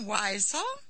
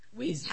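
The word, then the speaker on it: Wiesel: No, it's Weasel. I-E would be on the E. Would actually. – Okay. I'm just saying Weasel as in the animal Weasel. – Well, that's how it's pronounced. – Oh, is it? – Yes. – Oh, okay. – Yeah. Actually, it's Weasel. Weasel. – Oh, okay. – I mean, yep. if you know, that's how he pronounces his name.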